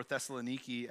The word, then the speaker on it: Thessaloniki: Thessalonica, or Thessaloniki, as it is pronounced in the Greek, was a city that was built and established by the Greek king Cassander of Macedon in 315 BC.